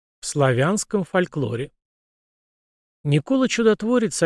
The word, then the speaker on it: Никола: При этом на Руси до реформы патриарха Никона, книжной справы, имя святого писалось и произносилось как Никола, и его изменения подвергали критике старообрядцы, в частности, протопоп Аввакум в послании об иконном писании.